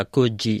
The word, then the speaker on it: Coogee: At sa Sydney suburb naman is, ano to, Coogee. Kuji. Kuji mo siya pronounce.